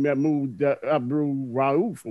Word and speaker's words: Mahmoud Abdul-Rauf: We've seen Mahmoud Abdul-Rauf or whatever his name is. Magic moment me on that one. – I think you actually said it right.